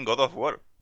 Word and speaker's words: God of War: es que te lo hayan hecho así, lo inesperado es que has pronunciado bien God of War.